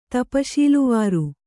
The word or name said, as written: ತಪಶೀಲುವಾರು